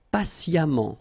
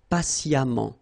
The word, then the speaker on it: patiemment: Patient, and then patiemment. So as I told you, even if you write it E-M-M-E-N-T, phonetically it goes like amant, patiemment, all right?